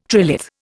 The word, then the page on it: trilith: Indian